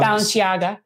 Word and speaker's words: Balenciaga: Uh, Balance, uh, ba- Balenciaga, is that how you say it, or Balen? – Uh, Balenciaga. – Balenciaga.